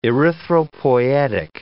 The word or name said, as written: erythropoietic